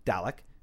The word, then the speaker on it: Dalek: By the time I finished watching episode 5, Dalek, am I pronouncing that right, guys? – Yeah. – Yes. – Okay. – You said that perfectly.